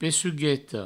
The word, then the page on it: pecugueta: pessugueto; provencal